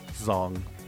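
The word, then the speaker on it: đồng: Zong. – Okay. – The Zong, although it's spelled with a D. The D makes dong. A Z. – Mm-hmm. – No, Top Gear pronounces it Dong.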